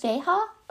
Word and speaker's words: Veja: I think they're called Veja, or I don't think you pronounce the J. I don't know. V-E-J-A.